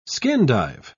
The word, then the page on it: skin-dive: British English